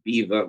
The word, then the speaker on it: Belvoir: Which is from a place called it looks like it's spelled Belvoir, but it's called pronounced Beaver, which is the home of the Duke of Duchess of Rutland.